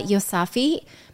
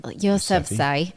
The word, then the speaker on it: Yousafzai: Malala is trending because human rights advocate and Nobel Peace Prize winner Malala Yousafzai, that's not how you say it, Yousafzai, was married in England on Tuesday.